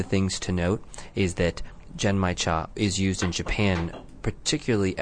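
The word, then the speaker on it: Genmaicha: The words Gen Mai Cha actually can be pronounced in a couple of different ways. The Japanese seem to say it as Gen Mai Cha as opposed to the Chinese version, Gin Mai Cha.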